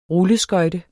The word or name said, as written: rulleskøjte